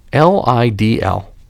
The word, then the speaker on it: Lidl: L I D L. Lidl. It rhymes with needle.